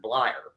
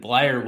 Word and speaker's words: Bleier: They swapped Matt Barnes from the Red Sox, went over in exchange for lefty reliever Richard Blyer. Or I don't know if I'm pronouncing that right, if it's Blyer or Blyer. – You got it.